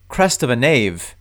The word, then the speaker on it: Crest of a Knave: but Crest of a Knave rhymes with Crest of the Wave.